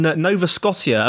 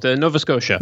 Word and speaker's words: Nova Scotia: And you're based up in Canada, in Nova Scotia. Have I said that correctly? – Almost, Nova Scotia.